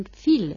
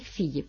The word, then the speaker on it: fille: pero no digan fil, digan ustedes fille, porque esa paletalización hace que se pronuncie la L, aunque no la E final.